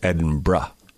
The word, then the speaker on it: Edinburgh: And she said it's Edinburgh. – Edinburgh. – Like B-R-U-H is the end. – Like Hebra. – Yeah. – Hebra. – So not Edinburgh, not Edinburgh, not Edinburgh, Edinburgh.